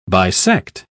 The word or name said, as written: bisect